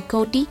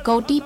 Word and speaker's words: Cote: publicado el 2016 por el historiador norteamericano Stephen Cotey. Creo que el apellido en inglés se pronuncia Cotey, pero si lo quieren teclear y googlear, el apellido se pronunciaría en español Cote.